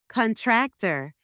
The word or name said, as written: contractor